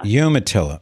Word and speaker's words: Umatilla: Am I saying the name correctly? I think it is correct, right? Umatilla? – Umatilla. – Ah, I was so close.